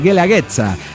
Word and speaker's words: Guelaguetza: Nada más que la pronunciamos muy mal. Helaguetza, dice, así se lee, pirata, mi amor. Sí, pero no sé si lo estoy leyendo bien porque sería como guerra, ¿no? Entonces sería... Guelaguetza, la Guelaguetza, donde todas las tradiciones e historias se conjugan en estas dos semanas.